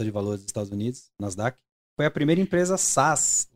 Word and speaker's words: Nasdaq: Nasdaq. É assim que pronuncia Nasdaq ou não? É isso, é isso.